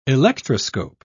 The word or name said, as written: electroscope